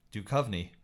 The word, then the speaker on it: Duchovny: What, how do you say it? – Duchovny. – Duchovny. Yes, that is correct.